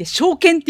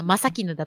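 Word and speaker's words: 正絹: あ、まさきぬ正しい絹と書いて証券って読むじゃん。それは読めんかったよね。読めなかった。ただ、ただ絹って読んでたりとかさ、まさ絹だと思っててさ、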